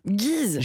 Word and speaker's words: Geese: C'est un groupe originaire de New York qui s'appelle, comment on prononce, Geese. – Geese. – G-E-E-S-E.